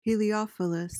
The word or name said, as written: heliophilous